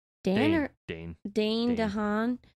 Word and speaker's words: Dane DeHaan: Dan DeHaan. – Dane DeHaan.